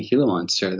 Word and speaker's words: Gila monster: The first, thank you for pronouncing Gila monster the way that you did. It's, you know, often that I hear people not from the area call them Gila monsters.